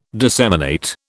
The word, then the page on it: disseminate: British English